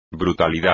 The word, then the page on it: brutalidad: espagnol